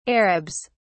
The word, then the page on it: arabs: British English